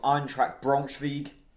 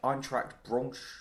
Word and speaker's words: Eintracht Braunschweig: He's from Eintracht Braunschweig. I think that's how you pronounce it. – Well done. – Eintracht Braunschweig.